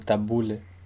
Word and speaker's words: tabulé: El tabulé, que viene del árabe, tabula, no sé cómo se pronuncia, pronunciado tabule en Líbano, es una ensalada típica de la beca, empleada a veces como un acompañamiento del metze, típico de la zona.